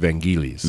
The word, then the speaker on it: Vangelis: But look at 1492, and because we're still talking about Vangelis here, or Vangelis, if you're Greek, that's how you pronounce it. Vangelis is how I pronounced it forever. Yeah, yeah. We'll get a debate about how to pronounce that. But a Greek friend... – Show number 12. – a Greek friend of mine straightened me out on this. It's pronounced Vangelis.